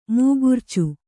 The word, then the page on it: ಮೂಗುರ್ಚಿ: Kannada